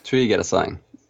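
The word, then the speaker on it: Trigedasleng: Trigedasling.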